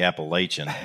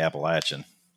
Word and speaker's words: Appalachian: We Northerners say Appalachian. We people that are correct say Appalachian.